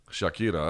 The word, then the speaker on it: Shakira: It's not Shakira? Shakira. You really did the foreign pronunciation on that. – Shakira. That's how you say it, dude.